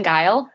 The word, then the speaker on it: Guile: We always say, or at least this Guile says, Kristen Guile rhymes with smile.